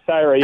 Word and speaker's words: Cyra: Syrah? Is that how you say it? – Yeah, C-Y-R-A, Syrah. Yeah.